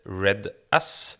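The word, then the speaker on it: RED-S: I dag skal vi ha om Reds. Før vi starta, så diskuterte vi om det her uttales Reds eller Red S. Men uh, i hvert fall, vi, vi lander på Reds.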